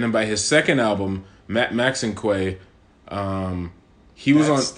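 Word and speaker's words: Maxinquaye: That's how you say it? – Yeah. – I always thought it was Maxinique. – No, Max and Quay, because it's a mixture of a few things.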